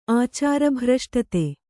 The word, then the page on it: ಆಚಾರಭ್ರಷ್ಟತೆ: kannada